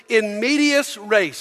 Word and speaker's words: in medias res: The first one is in medias res.